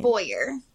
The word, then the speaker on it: Bowyer: I just need to ask how I say your last name. – Bowyer. – Bowyer, okay.